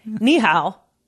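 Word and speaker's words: Nihao (你好): Nihao.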